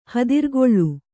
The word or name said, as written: ಹೆದರುಗುಳಿ